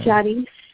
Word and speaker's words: Yariz: Jadis. – Spell it. – Y-A-R-I-Z.